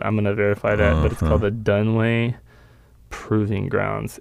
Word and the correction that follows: Dugway: It's called the Dugway. – Dugway. Yeah, you started with a D.